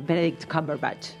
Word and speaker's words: Benedict Cumberbatch: El de la serie, eh, Benedict Cumberbatch.